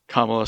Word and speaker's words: Kamala: Kamala, sorry. Emphasis on the wrong syllable.